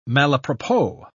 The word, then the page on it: malapropos: British English